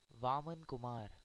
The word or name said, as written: Vaman Kumar